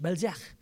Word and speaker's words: Balzac: I said, "Is that how you say it?" "It's French, Balzac."